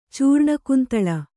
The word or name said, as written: ಚೂರ್ಣಕುಂತಳ